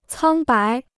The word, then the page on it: 苍白: cāng bái